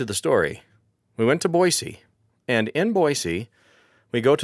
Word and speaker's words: Boise: And we say Boise, we don't say Boise, like Boise City in Oklahoma, for example. We say Boise. If you say it wrong, you're not from Idaho.